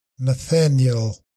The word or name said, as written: Nathaniel